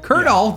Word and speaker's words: Colonel: That would be pronounced Colonel, but why do they do that? Why can't they just why does this happen to me? Why can't they just write Colonel? It says Colonel.